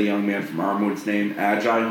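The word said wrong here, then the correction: Agiye: Ajay. – Ajay.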